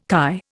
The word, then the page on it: chi: Indian